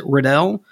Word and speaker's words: Riddell: I would pronounce it Riddell. – Okay. – R-I-D-D-E-L-L. – Sounds like Riddell to me. Sure. – I would call it Riddell.